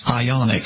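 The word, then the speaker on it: Ioniq: Ionic, yeah. – Yeah. – That's an interesting name. That's really kind of like blowing their own horn. It sounds like it's iconic. The Ionic, iconic.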